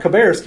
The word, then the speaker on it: Cabarrus: which is Columbus County, right? Cabarrus County. I'm sorry, I said that wrong.